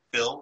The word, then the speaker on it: Phil: Yes, and then if you want to look for me, I am on Instagram at Phil, P-H-I-L, _ Boutte, B-O-U-T-T-E.